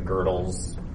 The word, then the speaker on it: Gödel: But yeah, so the if you get into, I think I pronounced his name correctly, Gödel's, pronounced Gödel, but yeah, it's spelled Gödel.